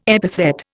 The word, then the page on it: epithet: Indian